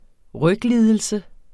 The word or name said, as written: ryglidelse